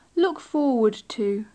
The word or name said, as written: look forward to